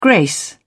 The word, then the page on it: grace: British English